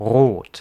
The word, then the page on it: rot: [ʀoːt]; [ʁoːt]; German